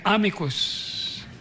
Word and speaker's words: amicus: Yun daw po Latin words, the accent is always on the first syllable. So we say amicus.